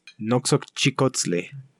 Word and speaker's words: Noxochicotzli: Noxochicotzli. – Noxochicotzli.